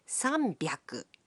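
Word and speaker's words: 三百: 300 is pronounced sanbyaku.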